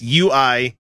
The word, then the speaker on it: Uyghur: It's Uyghur. I mean, I'm sure it's Uyghur, but it's just funny to say Uyghur. Chinese Uyghurs. – be Uyghur. – I looked up a pronouncer for it. It's Uyghur.